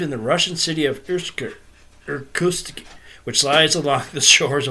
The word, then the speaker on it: Irkutsk: Irkutsk.